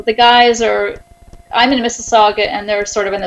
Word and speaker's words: Mississauga: How have you guys done up there in Mississauga? Did I say it right? – Mississauga. – Mississauga. – I'm in Mississauga, yes.